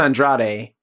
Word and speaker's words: Andrade: Well, I know it's Andrade, right? – The American version, yes.